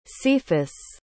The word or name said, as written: cephus